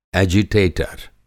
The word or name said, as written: एजिटेटर